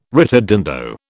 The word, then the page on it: ritardando: British English